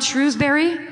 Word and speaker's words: Shrewsbury: Is it not called Shrewsbury? Am I saying it wrong? – Yeah, that was right. I'm saying, yeah, that was right. That's what you said earlier.